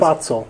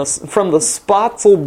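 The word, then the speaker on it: Spoetzl: From the Spitzer Brewery in Shiner, Texas. – Spatzel. Which, say it like you're in Texas. – From the Spatzel Brewery in Shiner, Texas.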